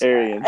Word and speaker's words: Arians: Bruce Arenas? – Arians. – Arians.